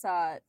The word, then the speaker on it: Coquille: So, anywho, on June 28, 2000, in, I think it's pronounced Coquille, Oregon. It's Coquille, Oregon.